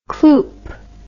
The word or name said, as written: cloop